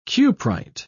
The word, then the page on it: cuprite: British English